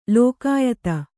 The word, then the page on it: ಲೋಕಾಯತ: kannada